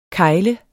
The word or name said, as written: kegle